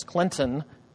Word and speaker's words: Clinton: And we do pronounce the T. Okay, it's not Clinton, it's Clinton, Mississippi.